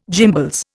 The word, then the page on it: gimbals: Indian